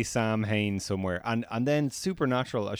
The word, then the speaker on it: Samhain: Not pronounced Samhain. – Samhain, my goodness.